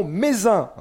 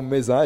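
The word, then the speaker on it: Mézenc: Chers amis, bonjour quel est le point culminant du département de Haute-Loire Le point culminant, c'est le mont Mézin. Hein. on ne prononce pas le C à la fin. 1753 mètres d'altitude pour le mont Mézin.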